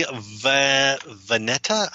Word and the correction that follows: Valletta: I think I'm terrible. I've forgotten how to pronounce his last name. He's great. If you go, Valletta. – Giovanni Valletta. – Yeah, Giovanni Valletta.